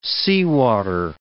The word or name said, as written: seawater